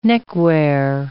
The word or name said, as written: neckwear